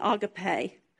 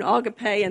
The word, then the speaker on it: agape: And if I jog back and forth between agape and agape, forgive me. All my life I pronounced that agape, but my talking Strong's says agape.